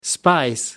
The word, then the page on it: spice: American English